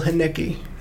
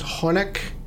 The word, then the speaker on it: Haneke: Well, if anyone didn't listen last week, this week we are discussing the 1997 and 2007 movie Funny Games by, how do you pronounce Michael's last name? – Michael Haneke. – That's how you pronounce it. – Well, okay. That's how you pronounce it in the American accent. – I don't even want to try. – And, you know, because I thought it was Haneke, then I thought it was Honeck.